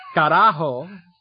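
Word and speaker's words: caralho: The best way to put it is the L is silent, so it's carajo, And then in English, VOA would be one syllable, but in Portuguese it's two.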